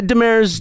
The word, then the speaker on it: Demers: I'm saying your name right, Demers, right? – Demers. Demers. Yeah, it's a. – I just realized. – Yeah. No problem. – Demers is a more French kind of.